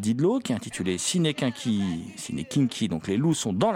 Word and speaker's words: quinqui: Ça s'écrit kinky, hein, Q-U-I-N-Q-U-I, mais ça se prononce kinky, qui est mise à l'honneur, ce cinéma, ce type de cinéma, dans un superbe coffret sorti chez Artus Film.